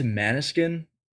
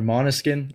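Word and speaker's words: Måneskin: Um, uh, what I went to Maniskin uh, recently, or Moniskin. I think is better, better pronouncing it.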